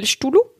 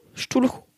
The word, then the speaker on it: Cthulhu: Qu'on prononce mal depuis le début? Il s'appelle Cthulhu. Cthulhu. Cthulhu. – C-T-H, t'as raison. C-T-H-U-L-H-U. Et après Chronicles.